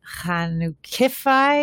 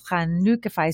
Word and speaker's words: Chanukah-fi: Yes, it's not Chanukah-fi. It's Chanukah-fi. It's just the emphasis.